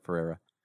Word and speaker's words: Ferreyra: That's not how you say his name, by the way. – Because of Juan Ferreyra. There's an R missing there. Ferreyra.